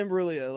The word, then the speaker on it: Imbruglia: Like, there's no way that was Imbruglia. Imbruglia. – There's a G in there. – Yeah, no, but it's Natalie Imbruglia. Like, that's how you pronounce it.